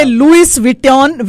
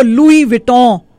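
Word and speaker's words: Louis Vuitton: जैसे लिखेंगे लुइस विटन विटन लेकिन उसको लुई विटों.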